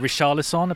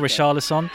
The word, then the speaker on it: Richarlison: He pronounces it Richarlison, apparently that's how you say it in Brazil, Richarlison.